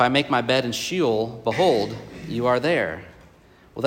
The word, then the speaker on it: Sheol: and it's spelled S-H-E-O-L. I think it's pronounced Sheol.